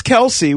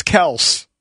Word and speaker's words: Kelce: Travis Kelce was on, or Travis Kelce. Remember, he said that's how his name is pronounced? – I'll never call him Travis Kelce, ever.